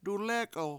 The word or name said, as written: dulEk$1l